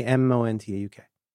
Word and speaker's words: Montauk: Okay, how do you how do you say M O N T A U K? Montauk. – You got it.